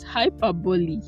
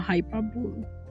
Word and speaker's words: hyperbole: So it's hyperbole, not hyperbole.